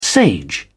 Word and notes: sage: British English

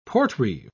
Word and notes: portreeve: British English

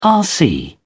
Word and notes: rc: British English

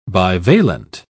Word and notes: bivalent: British English

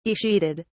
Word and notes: escheated: British English

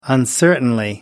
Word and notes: uncertainly: British English

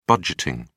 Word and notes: budgeting: British English

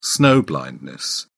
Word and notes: snow blindness: English; British English

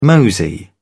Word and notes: mosey: British English

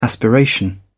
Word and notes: aspiration: British English

